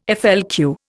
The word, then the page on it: FLQ: Indian